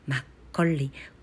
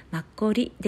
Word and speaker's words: マッコリ: 正確な韓国語の発音はマッコリマッコッリコの下に、えー、パッチムといってね、えー、舌を R くるりと回す発音が正確な発音ですマッコリ